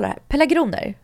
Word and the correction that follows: pelargonier: Pelargonier. – Och jag säger alltid pelargoner.